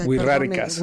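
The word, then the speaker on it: Wixárika: No, huiziracas, ¿cómo se pronuncia? – Huiráricas. – Huiráricas.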